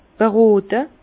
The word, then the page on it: berote: Alsatian; Bas-Rhin; Herrlisheim